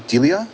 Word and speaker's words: Delia: D-E-L-I-A. – Delia? Is that how you say it? – Yeah. Well, I pronounce the E like an I, so Delia.